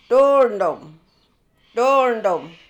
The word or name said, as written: Thoowerndem